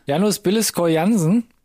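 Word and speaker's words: Janus Billeskov Jansen: Möchtest du den Namen aussprechen? Janus? Janus Billeskor Jansen? Ja, sehr schön.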